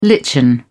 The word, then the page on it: lichen: British English